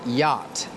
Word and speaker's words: yacht: The next word is yacht. This is a difficult word because it has two letters that are silent. The two letters are C and H.